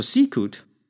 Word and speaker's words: sicut: So sicut is a two-syllable word, just meaning as.